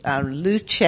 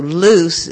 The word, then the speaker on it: Luce: And the restaurant we're talking about is Luce, if you are spelling it or pronouncing it as the owners would like, for Italian for light, and there's a wine connection with the Frescobaldis as well, but also loose if you're talking to Dominique, because that's French for light, and that's what she's not cooking Italian here.